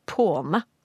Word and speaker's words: pwne: For i norsk slangordbok så finner vi, at det, da finner vi både formen PWNE og PAWNE, som da er tilpasset til uttalen. For vi sier påne.